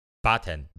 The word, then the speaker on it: Batten: I thought you said batter. I was like, what? – Batten, B-A-T-T-E-N, Batten disease.